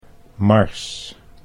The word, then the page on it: mars: Dutch